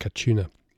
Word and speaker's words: Katuna: Spelt Katuna with a K, but I think pronounced Kachuna.